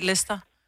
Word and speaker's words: Leicester: Altså, der er jo bare nogle ting, du ikke udtaler. Det staves jo også sådan noget Leicester, jo. – Men bliver bare udtalt Leicester.